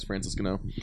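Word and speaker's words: Ngannou: That one's got Overeem versus is it Nagano? How do you say that? – What's the whole name? – Francis Nagano. – Let me see that. I think it's Francis Gano. – It's Francis Gano.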